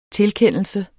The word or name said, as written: tilkendelse